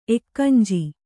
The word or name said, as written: ಎಕ್ಕಂಜಿ